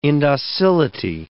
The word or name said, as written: indocility